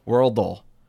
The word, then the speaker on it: Worldle: Worldle. It's not Wordle, folks. No. We know what Wordle is.